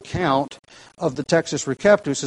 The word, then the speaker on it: Receptus: Usually pronounced Textus Receptus, but that's really a mispronunciation of the Latin. There is no soft C in the Latin. And so it's Receptus. Textus Receptus would be a better pronunciation.